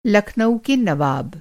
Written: नवाब

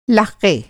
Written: लाक़ा

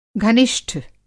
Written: घनिष्ठ

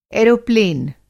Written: एरोप्लेन

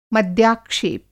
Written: मद्याक्षेप

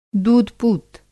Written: दूध-पूत

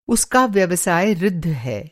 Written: ऋद्ध

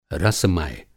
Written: रसमय

